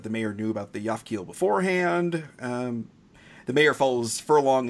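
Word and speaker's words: Japhkiel: The presenter is the mayor of Tokyo, when he tells the group that the robot is called a Yafkiel.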